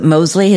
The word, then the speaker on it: Mosley: Your lead characters, William, is it Mosley? Is that how you say it? – Correct.